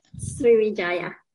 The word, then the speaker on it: Sriwijaya: I'm going to butcher this, Sriwijaya, right? – Sriwijaya. – Ah, there we go.